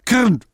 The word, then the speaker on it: Krn: Es gibt im Tschechischen, oh ja, man müsste ein Zungen-R können. Die Deutschen können das nicht aussprechen. Ich buchstabiere mal. K-R-N. Kirn. Ja, etwa in der Richtung.